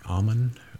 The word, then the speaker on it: Amman: Is that how you pronounce it? Amman, Amman, an Arab state. – Sure. – Calls himself the Fetch, F-E-T-C-H.